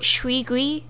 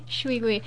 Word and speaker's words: shuigui: I think you got it right. Shuigui. – Shuigui. – Shuigui. – Just keep thinking of squeegees.